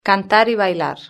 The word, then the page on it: cantar y bailar: European Spanish